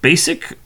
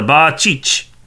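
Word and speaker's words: Bacic: And the last name apparently is pronounced Basic, although the original pronunciation, from what I have been told online, and of course the internets are never wrong. – Never wrong. – But it's Ba-Cheech.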